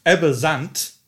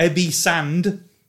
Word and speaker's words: Ebbe Sand: Ebbe Zandt is the correct pronunciation, not Ebbe Sand.